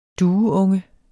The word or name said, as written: dueunge